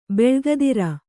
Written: ಬಳಗಧಾರೆ

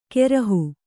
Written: ಕೆರಹು

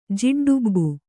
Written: ಜಿಡ್ಡುಬ್ಬು

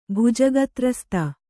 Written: ಭುಜಗತ್ರಸ್ತ

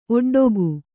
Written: ಒಡ್ಡಿಕೆ

